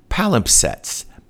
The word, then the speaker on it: palimpsest: And apparently it's pronounced palimpsest, palimpsest, palimpsest.